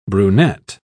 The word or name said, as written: brunette